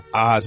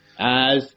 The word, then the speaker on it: Oz: Hey, thanks for joining us today from Oz. And am I pronouncing it right? Isn't it Az? – Az. You can say Az or just Australia.